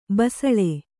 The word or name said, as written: ಬಿಸಿಲ್